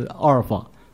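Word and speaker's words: 阿尔 法 (ἄλφα): 我是阿尔法，我是 Omega、啊、真正的读音是这样读的，阿尔法跟 Omega，可是我们中文把它翻译成阿拉法跟俄美甲。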